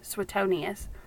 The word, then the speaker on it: Suetonius: Suetonius.